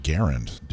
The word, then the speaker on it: Garand: You were supposed to correct my pronunciation of Grand. – Oh, he's given up on you. – Yeah. Actually, it's pronounced Garand.